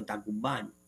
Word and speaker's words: 打鼓番: 然后这个打鼓番其实就是阿美族语关山的意思，念作打鼓番，就是阿美族语，就是关山的意思。